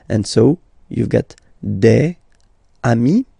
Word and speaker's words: des amis: And if you make the liaison, so the link between the two, you will get des amis, okay? Des amis. So amis, remember, doesn't change. Even if you get to write the S, then you don't pronounce it, okay?